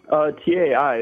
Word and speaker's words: Tai: Tai, the Chinese way, but my parents are very white, and so am I.